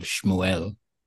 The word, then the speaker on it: Shmuel: playing the role of Shmuel. – Nicely done. Most people can't say that right.